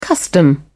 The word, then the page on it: custom: British English